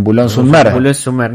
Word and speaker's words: Boulogne-sur-Mer: En Boulogne-sur-Mer. – No lo quería decir porque la iba a pronunciar mal. – No, muy bien. – Boulogne-sur-Mer.